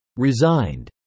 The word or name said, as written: resigned